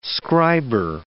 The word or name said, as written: scriber